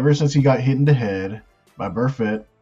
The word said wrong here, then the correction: Vontaze Burfict: or Burfitt, whatever how you pronounce that super last name. – Vontaze Burfict.